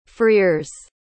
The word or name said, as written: freers